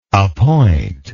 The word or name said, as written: appointer